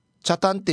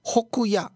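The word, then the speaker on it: 北谷: ああ、北の谷と書いてチャタンって読めずに北野って間違えちゃうんですよね。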